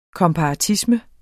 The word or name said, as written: komparatisme